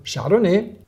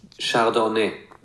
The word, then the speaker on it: Chardonnay: Das ist die deutsche Aussprache von Chardonnay. – Ja. – Wir haben eine ganz leichte Betonung. Wenn wir die Lautschrift lesen, haben wir eine leichte Betonung auf der dritten Zeile. Wir haben aber dieses Epsilon, das ist das kurze E wie in Welt.